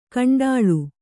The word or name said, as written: ಕಂಡಾಳು